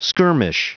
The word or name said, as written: skirmish